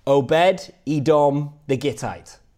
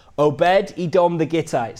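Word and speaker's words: Obed-Edom the Gittite: Obed Edom the Gittite. Is that right? Is that right, Nigel? Okay. Obed Edom the Gittite.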